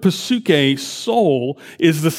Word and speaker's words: psyche: You want to say psuche? Psuche. It sounds very different in Greek when you pronounce it with the P, but in English, we use this word all the time, but we leave the P silent. It's still written there, but it's in words like psyche.